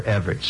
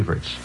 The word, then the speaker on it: Evert: In fact, did you read Chris Evert's, or if you prefer Everett's, see, that's the mispronunciation, it's actually Evert's.